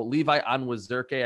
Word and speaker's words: Levi Onwuzurike: So Levi Onwuzurike, I believe is how it's pronounced,